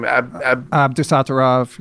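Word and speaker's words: Abdusattorov: Nota, whatever his name, Abdusatarov.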